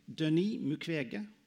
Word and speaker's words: Mukwege: to Denis Mukwege, or Denis Mukwege, as I've been told the right pronunciation is,